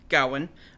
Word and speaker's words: Gawain: But basically, it's about Sir Gawain, or in the movie, Gawain, which would be the correct pronunciation,